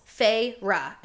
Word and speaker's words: Feyre: Two syllables, Feyre, and they spell it out.